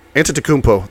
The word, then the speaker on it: Antetokounmpo: But Antetokounmpo. There we go. – That's pretty close.